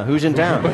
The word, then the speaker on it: Hu Jintao: Yeah, Hu Jintao.